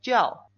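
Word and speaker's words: Jiao (叫): Pronuncia más las vocales. – Sí. – Jiao. – Jiao. – Mucho mejor. Jiao.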